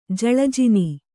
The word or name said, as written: ಜಳಜಿನಿ